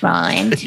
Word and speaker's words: Rowland: Yeah, but it's R-O-W-L-A-N-D. So Rowland. – How they say it in the documentary. – I think it sounds like Roland, but still. – It's Roland.